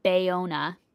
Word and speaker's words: Bayona: It was directed by J.A. Bayona. I think I'm saying that right. – Yep.